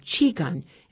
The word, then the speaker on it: Qigong: Here's the pronunciation. Qigong.